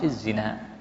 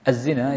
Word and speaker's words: الزنا: نعم، يقول الشيخ أولا يسن، أي يسن النكاح مع شهوة لمن لم يخف الزنا. الزنا يصح بالمد والقصر، وهما لغتان فصيحتان النطق فيهما سواء، وإنما الاختلاف في الرسم.